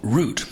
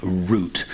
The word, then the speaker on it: route: And the interesting thing there is the pronunciation of route, because here in the United States, the most common pronunciation of the word is route. Here we have root, and indeed in Bobby Troup's own recording of the song, he sticks to root.